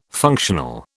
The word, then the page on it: functional: British English